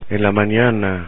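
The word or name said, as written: en la mañana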